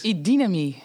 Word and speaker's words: Idynami: Daarna projectmanagement en coaching bij Idynami, zeg ik dat goed? – Idynami.